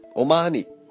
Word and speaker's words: O'Mahony: Paul, Paul, I'm going to read it non-phonetically. Paul O'Mahony. I know it's Paul. He says it's pronounced different. Omani. Omani. Yeah, it sounds like money.